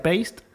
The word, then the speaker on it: paste: Que me acuerdo es Repli, que las, que pronuncio como se me canta. – Repli, sí. – Em, paste, que en, vez de, perdón, reply sería reply y paste sería paste.